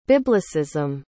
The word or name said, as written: biblicism